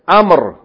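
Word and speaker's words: عمرو: In the first example, Aleph, they gave هذا الطالب اسمه عمر with a waw following it. That waw is not pronounced. What does it mean? Huh? This student. His name is Amr.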